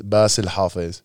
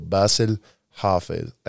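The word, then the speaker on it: Bassil Hafez: So it's basically, it's just hard because it's the pronunciation of the S is a little softer and it's kind of like riding it out with still pronouncing, like, think of it like Basel. So Basil Hafez. And then the Hafez is a ha. And it's like a, like almost like a throaty ha.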